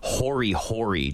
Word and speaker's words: hori hori: So it's called the Hori Hori knife. So I really liked that she mention that. And I should say it's hori hori, just if you're going to say it like a Japanese person, it's hori hori.